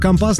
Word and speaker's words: компас: Ну и чтобы компас не подводил, кажется, так ударение ставится у вас.